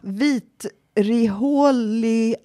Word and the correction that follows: vitriolhaltigt: Hur uttalar man det där? – Får jag se? – Vitrihålig. – Vitriolhaltigt vatten. – Vitriol. Ja. Okay.